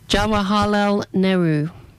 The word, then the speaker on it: Jawaharlal Nehru: Jawaharlal Nehru.